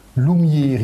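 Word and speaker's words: Lumîr: Claudel insiste sur la prononciation. Loumir, il faut prononcer comme ça, ça s'écrit L-U-M-I, accent circonflexe, de nouveau R.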